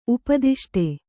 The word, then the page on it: ಉಪದಿಷ್ಟ: upadiṣṭa